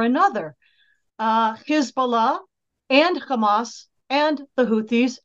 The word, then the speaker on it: Hezbollah: And by the way, I always say Hezbollah because in pronouncing it that way, you get the meaning of the word. Hizb, H I Z B in English. Um, the Arabic meaning of that is party. Allah, God. Allah is the Arabic word for God. So Hezbollah, the party of God. But if you say it any other way, you lose that meaning.